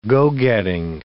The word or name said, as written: go-getting